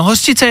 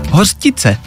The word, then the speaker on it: hořčice: No hořčice je to správně, hořčice, ale spousty lidí vyslovuje hořtice.